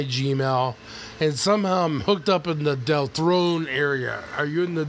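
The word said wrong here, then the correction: Delthorne: It's pronounced Del Thorne, but yeah, it's kind of like a mix between Hawthorne and Del whatever Del is supposed to be.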